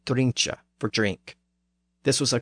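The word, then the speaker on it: drunche: So it has an E at the end, pronounced eh, drincha.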